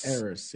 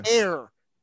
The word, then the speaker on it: Eris: Eris, I have to ask you: am I pronouncing that Eris? Eris? – Eris. – Eris. – Eris. Yeah. – Air. Air, light as air.